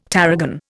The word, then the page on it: tarragon: Indian